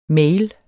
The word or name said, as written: mail